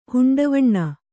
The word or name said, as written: ಹುಂಡವಣ್ಣ